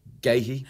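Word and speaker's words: Guéhi: Of course, John Stones, Mark Gay, how do I say his surname? Mark Gahey, yeah.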